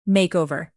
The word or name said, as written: makeover